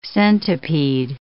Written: centipede

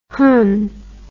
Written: hunh

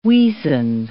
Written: weasand

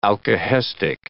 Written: alkahestic

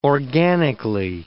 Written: organically

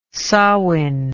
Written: samhain

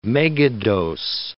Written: megadose

